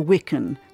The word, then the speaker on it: wiccan: The rowan or wiccan, rhyming with chicken, was a sign of affection.